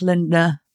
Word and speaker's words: Lindner: Good luck, Lindner. I got the name right, didn't I? – Yeah, you did.